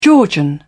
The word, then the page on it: georgian: British English